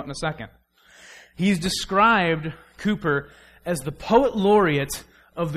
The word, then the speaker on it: Cowper: Looks like Cowper, pronounced Cooper, a weird British thing.